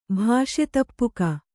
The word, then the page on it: ಭಾಷೆತಪ್ಪುಕ: Kannada